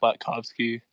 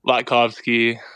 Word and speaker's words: Latkovski: It's pretty much, it seems like intimidating when you see it, but it's pretty much how it's spelled, just Latkovski. – How do you say it? – Latkovski.